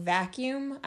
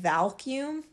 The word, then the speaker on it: vacuum: But I don't know if I just mispronounced it, but instead of saying vacuum, I said valcume, like it had an L in there. And I think I just mispronounced it, but my parents rolled with it. Like my siblings rolled with it. It was probably an embarrassing, embarrassing long time before I realized that there was no L in vacuum.